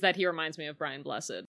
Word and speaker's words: Brian Blessed: Brian Blessed. – Megan, you said it wrong. – I can't say it like him. – It's Brian Blessed!